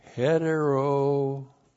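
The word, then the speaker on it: Heterozugeo (ἑτεροζυγέω): Heterozugeo. Isn't that a humdinger of a word? That's a great one, isn't it? There's the letter H right there. H-E-T-E-R-O-Z-U-G-E, long O. Heterozugeo.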